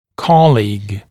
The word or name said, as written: colleague